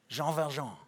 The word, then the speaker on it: Jean Valjean: You have to say it like that because it's French. Jean Valjean.